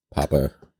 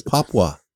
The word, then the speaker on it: Papua: Papa. – No, it's Papua. – I think, isn't it not pronounced Papa? – It, no.